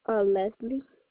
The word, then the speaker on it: Leslie: Leslie. – L-E-S-L-I-E? – Yeah.